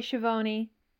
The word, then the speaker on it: Schiavone: Everybody in the family says it differently. Like, I say Schiavone.